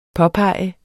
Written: påpege

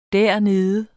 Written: dernede

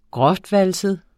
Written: grovvalset

